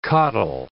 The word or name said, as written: coddle